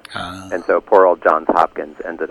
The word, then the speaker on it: Johns Hopkins: That Johns has an S on it. No, most people are called John, so you figure it's John Hopkins, but it's Johns Hopkins.